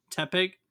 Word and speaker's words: Tepig: abuses his Tepig, or T-Pig, how do you say that Tepig. – One? Tepig.